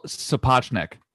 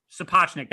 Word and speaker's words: Sapochnik: It was created by Ryan Condal, who cut his teeth on Colony, which was a solid show, and Miguel Sapochnik, who I know I'm butchering that. – Miguel Sapochnik. – Sapochnik. That makes way more sense and sounds completely more accurate.